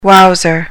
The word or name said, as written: wowser